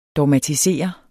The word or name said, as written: dogmatisere